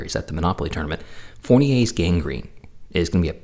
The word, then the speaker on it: Fournier's: The first one is Fournier's gangrene. The R is silent, don't worry. It's French.